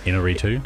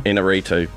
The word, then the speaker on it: Iñárritu: Iñárritu. – Iñárritu, of course.